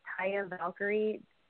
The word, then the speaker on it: Taya Valkyrie: Is it Taya Valkyrie? Is that how you say her name? – Yeah, I believe you got it right.